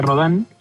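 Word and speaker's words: Rodin: No, las mejores tortas están en la esquina de Museo y Augusto Rodán. Se escribe Rodín, pero se pronuncia Rodán.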